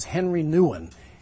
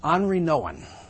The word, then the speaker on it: Henri Nouwen: I used to think his name was Henry Nouwen, but you pronounce it French. It's Henri Nouwen.